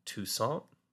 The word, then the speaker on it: Toussaint: The Bobby Blue Band and New Orleans R&B artist Alan Toussaint. – Mm-hmm. – Look at me with my French pronunciation. – I mean, it was fine. – It was perfect.